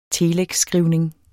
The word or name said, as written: telexskrivning